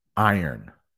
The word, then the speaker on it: Iron: So if you can say earn, I know that R might be hard, but it's I earn. I earn. Iron.